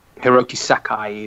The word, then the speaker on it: Hiroki Sakai: So, yeah, Hiroki Sakai, his name is.